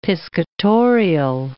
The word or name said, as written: piscatorial